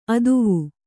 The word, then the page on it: ಅತ್ವ: Kannada